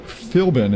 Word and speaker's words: Philbin: Jeff French and Mike Philbin is how you say it. – Correct.